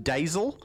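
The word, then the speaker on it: deiseil: Which I've got here spelled three different ways, but I'm assured that they're basically pronounced daisel, cool.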